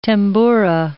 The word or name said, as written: tamboura